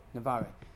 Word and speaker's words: Navarre: Then you had Aragon, and then Navarre, and how do you say it? – Navarre.